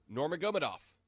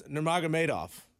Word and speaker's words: Nurmagomedov: How do you say his name? – Nurmagomedov. – No, it's Nurmagomedov.